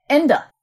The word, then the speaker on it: Ende: And then I looked to see when Michael Ende, I don't know if I can pronounce his name correctly. It's German. Ende. Thank you. Michael Ende.